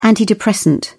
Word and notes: antidepressant: British English

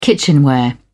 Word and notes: kitchenware: British English

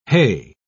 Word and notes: heigh: British English

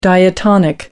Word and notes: diatonic: American English

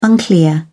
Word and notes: unclear: British English

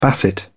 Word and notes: basset hound: British English

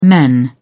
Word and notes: men: British English

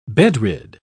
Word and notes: bedrid: British English

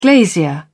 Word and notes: glazier: British English

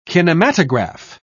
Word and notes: kinematograph: British English

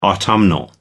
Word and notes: autumnal: American English

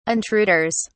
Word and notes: intruders: British English

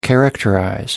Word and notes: characterize: American English

